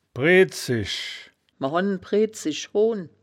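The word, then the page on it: bredzisch: Wittlicher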